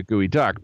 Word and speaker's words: geoduck: So, you know, okay, gooey duck, who knows why it's called a gooey duck. But it turns out that it's spelled G-E-O-D-U-C-K, geoduck. That makes no sense.